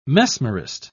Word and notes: mesmerist: British English

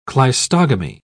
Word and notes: cleistogamy: British English

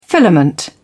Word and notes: filament: British English

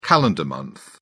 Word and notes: calendar month: British English

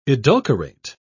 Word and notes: edulcorate: British English

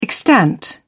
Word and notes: extant: British English

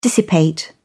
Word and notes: dissipate: British English

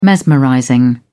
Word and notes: mesmerizing: British English

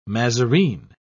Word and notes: mazarine: British English

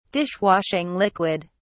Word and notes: dishwashing liquid: British English

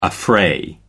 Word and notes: affray: American English

